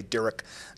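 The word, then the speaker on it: Derrick: So if you search for my name on YouTube, it's D-E-R-R-I-C-K, Derek. Lots of ways to spell it.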